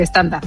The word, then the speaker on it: estándar: Simplemente el estándar, nada más. Y así, estándar con E al principio y acento en la estándar.